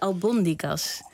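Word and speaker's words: albóndigas: Ja, ze zetten in Spanje vaak eventjes een accentje op de lettergreep waar de klemtoon komt. En ook al zouden wij liever albondigas willen zeggen, want zo zijn wij Nederlanders. Maar nee, hij moet op de tweede.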